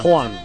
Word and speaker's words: Juan: Ah, ben lui aussi, il faut le prononcer en... Le, oui. – Ce J-là, c'est Juan. – Juan.